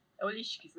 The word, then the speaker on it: Holistics: E, como que você pronuncia a marca? Só uma dúvida que eu fiquei. – Holistics. Tá errado, tá certo? – Eu falava Holistics. – Holistics, né? – Ela falou Holistics, tipo, meio que americanizado.